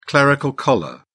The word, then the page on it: clerical collar: British English